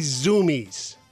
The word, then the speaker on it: Zumiez: Based on the pronunciation, you'd think it's spelled Z-O-O-M-I-E-S. Not even close. Zoomies is spelled Z-U-M-I-E-Z.